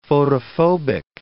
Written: photophobic

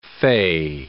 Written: fay

